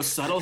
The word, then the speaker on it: subtle: Did you know subtle has a silent B in it? – Oh, man.